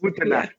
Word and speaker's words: Gute Nacht: So I think that is Gute Nacht, right? Did I say it right? Gute Nacht. – Gute Nacht. – Yeah, been a while.